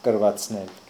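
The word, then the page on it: krvatsnel: Russian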